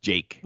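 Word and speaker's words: JVKE: Just Jake? – Well, it's J-V-K-E, but it's pronounced Jake.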